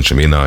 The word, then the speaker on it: Chimena: Und ein Titel davon, nämlich Chimena, kommt in die, so spricht man Chimena, ein Name nur Spanisch gesprochen, wie du sagtest, als eine Neuvorstellung auf 8 Wochen in die Hitparade.